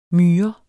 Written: myr

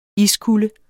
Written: iskulde

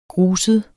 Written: GRUSEDE

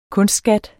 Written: kunstskat

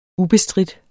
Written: ubestridt

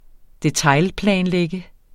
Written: detailplanlægge

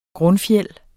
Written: grundfjeld

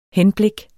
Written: henblik

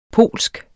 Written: polsk

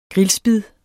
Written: grillspid

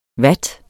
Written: vat

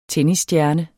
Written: tennisstjerne

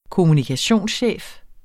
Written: kommunikationschef

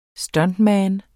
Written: stuntman

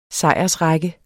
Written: sejrsrække

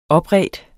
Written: opredt

